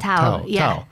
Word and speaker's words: Thao: Tao? Is that how she says her name? Tao. – How do you spell it? – T-H-A-O. Tao. – Yeah. – Tao.